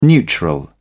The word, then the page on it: neutral: British English